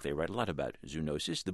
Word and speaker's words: zoonosis: My vocabulary word for the week is zoonosis, or some say zoonosis. In any case, it's spelled Z-O-O-N-O-S-I-S, zoonosis.